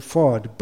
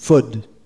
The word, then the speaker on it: ford: and then ford, Bradford, Hereford. It's not pronounced ford, it's pronounced food.